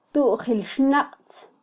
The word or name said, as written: tujilxnaqtz